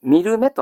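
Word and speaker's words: 海松: 発音は全く違うんです。見る目と発音します。見る目です。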